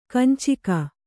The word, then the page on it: ಕಂಚಿಗ: kanciga